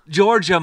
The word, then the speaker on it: Giorgia: Giorgia Meloni.